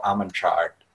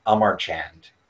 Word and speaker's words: Amarchand: They're the Indian law firm, and excuse me if I mispronounce this, but Cyril Amanchard, or Amarchand, I believe is how you pronounce it.